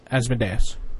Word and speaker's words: Asmodeus: Was it Asmodeus? – Asmodeus. – Asmodeus. Oh, like Deus.